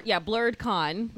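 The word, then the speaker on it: BlerdCon: Blurred Con? – Blurred Con. – How do you spell that? – B-L-E-R-D-C, because it's in D.C. O-N. – B-L-E-R-D-C.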